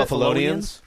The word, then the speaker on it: Buffalonians: If Buffalo, Buffalans, how are you pronounce it? – Buffalonians.